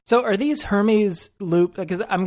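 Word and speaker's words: Hermès: I thought it was pronounced Hermes, but I don't think that's correct, actually. They're pronouncing it in some really fancy, highfalutin way that I don't think I can. – Hermes.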